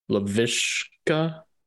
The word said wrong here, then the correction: Laviska: Laviska Shenault. Yeah, he looked great.